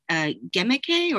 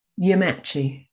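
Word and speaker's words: gemæcce: And I'm especially fascinated by the use of the term, and forgive me if my pronunciation is terrible, but Gemache or... – Yamachi. – Yeah, okay, yes, which is the name of your blog as well.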